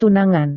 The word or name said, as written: tunangan